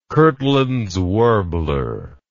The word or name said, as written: kirtlands warbler